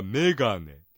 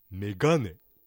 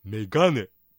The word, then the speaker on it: メガネ: はいはい、例えばメガネという言葉ね、標準語ではメガネ、ね、目にアクセントを置いてますが、新潟弁ですと 2 文字目のガにアクセントを置いてメガネ、ね、メガネと発音します。